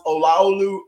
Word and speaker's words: Olaolu: So my name is pronounced Olaolu Ogunyemi. And I'm glad we have it phonetically spelled there because, you know, like I said, some people, we tend to butcher it.